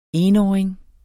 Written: enåring

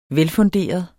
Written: velfunderet

